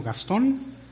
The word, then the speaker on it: Gastón: Y si no saben mi nombre, me llamo Gastón. Se los deletreo con mucho gusto. G-A-S-T, ¿qué sigue? G-A-S-T-O-N. Acento en la O, porque es palabra aguda, que es la única regla de acentuación que me aprendí en la escuela.